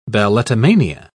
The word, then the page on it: balletomania: British English